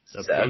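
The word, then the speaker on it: Szapucki: Number nine, I'm a butcher the name, Thomas Zaspuki. Think I said that right. – Zaspuki. – Okay. Zaspuki. Okay. – I think it's Zaspuki. At least that's what I say.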